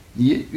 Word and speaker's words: nier: Mais je vous dis, alors, dans le cours du 16e, 17e siècle, alors, prononciation actuelle qui s'installe, nier une seule syllabe.